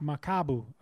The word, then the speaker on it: Makabu: Makabu. – Makabu.